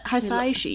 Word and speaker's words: Hisaishi: Hisaishi. – Yeah, we figured out how to say it. Hisaishi.